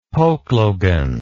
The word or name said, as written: pokelogan